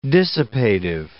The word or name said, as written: dissipative